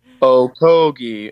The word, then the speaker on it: Okogie: As if somebody needs to know how to pronounce Knox, K-N-O-X. – Any other good names on there? – No. Josh Okogie is O-H-K-O-G-E-E. Okogie, and KO is capitalized.